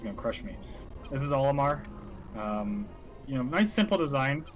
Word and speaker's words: Olimar: And the main character, his name is Olimar, right? – Yes. – Olimar. I don't know if I'm pronouncing that right, but yeah, it is indeed Olimar.